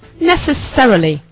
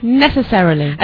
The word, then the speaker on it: necessarily: Number two, necessarily. Now, can you say that? – 它有两种读音。 – Necessarily. – 啊，对，一种是 necessarily, 一种是 necessarily. – Yeah. – 英国人读的比较多的是 necessarily, 对吧？ – Don't we all say necessarily? – 美国人喜欢说 necessarily.